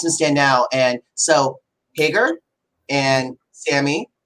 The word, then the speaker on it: Hager: How does Jake pronounce his last name? It's Hager, right? – Hager, yeah. – Hager, cool. I was making sure I wasn't saying it wrong.